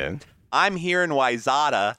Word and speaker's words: Wayzata: For those of you who don't live in the Twin Cities area, it's pronounced Wayzata.